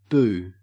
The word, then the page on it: boo: Australian English